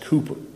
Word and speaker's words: Cowper: William Cowper, it's spelled Cowper, but it's pronounced Cooper, is known by many as one of the greatest English hymn writers in church history.